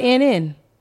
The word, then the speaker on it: Lynn: Might be Lynn. – Yes, it is. It is. Linn.